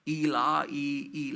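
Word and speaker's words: Eli: Ilai, Ilai, lama sabachthani is the pronunciation in the Aramaic language.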